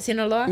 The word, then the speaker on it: Sinaloa: Did I say it right? Sinaloa? – Mm-hmm.